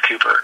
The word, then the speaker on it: Cowper: Also, too, with his speaking of depression, his relationship with William Cooper, looks like Cowper, if you ever look it up, but okay. It's pronounced Cooper, C-O-W-P-E-R.